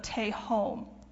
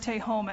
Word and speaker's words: Tehom: And I called it the Tehom. That's spelled T-E-H-O-M.